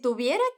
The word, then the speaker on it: tuviera: Esse verbo conjugado assim, Tuviera se escreve com V e a Juana fala esse V com o som de B do espanhol, aquele mais sutil porque a letra V está entre vogais. Estuviera.